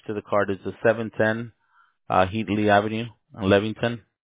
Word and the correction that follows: Levittown: Yeah, Headley in Levittown. – Levittown. Sorry for the mispronunciation, brother. Right. – No, it's... H-E-A-D-L-E-Y? – Correct.